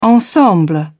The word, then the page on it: ensemble: British English